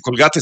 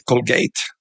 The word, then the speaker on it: Colgate: Señor, entonces, bueno, no se dice Colgate, se dice Colgate.